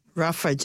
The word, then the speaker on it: Ruffage: The name of the business is Ruffage Dog Treat Co. – You have to spell it, Yeah, roughage is spelt. Yeah. – R-U-F-F-A-G-E. – Yeah, roughage, as in rough, rough.